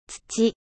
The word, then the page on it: terre: French